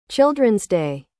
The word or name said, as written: Children's Day